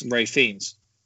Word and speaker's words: Ralph: I saw earlier that it's not pronounced Ralph Fiennes, which is Ray Fiennes.